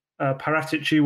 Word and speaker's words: Paratici: And obviously, Paratici will have done his job behind the scenes as well. Yes, I pronounced it correctly.